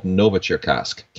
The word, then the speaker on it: Novocherkassk: Novocherkassk. Can't believe I pronounced that correctly. – Wow, that sounded perfect.